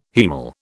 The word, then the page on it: haemal: British English